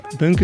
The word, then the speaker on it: bunq: Is het bank of bank of bunk met een Q? Hoe zeg jij het? Eh, bunk is eh, Bank of the Free, dus je mag het uitspreken zoals je wil.